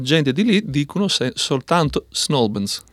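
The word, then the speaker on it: St Albans: Attenzione, Snowbans, due sillabe e basta. – Snowbans. – Snowbans. – Quindi si perdono via la T. – Sì, esatto. Sì, riducono al massimo perché l'accento va sulla prima sillaba di Albans e il resto viene ridotto.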